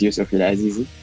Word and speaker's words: Youssef El Azizi: So my name, like you pronounce it correctly, my name is Youssef El Azizi.